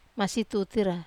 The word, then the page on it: masi tutira: Wamesa